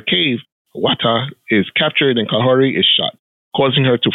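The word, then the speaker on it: Kahhori: In the late 15th century, young Mohawk siblings, Kahore and Wata, did I say that correctly? – Yes.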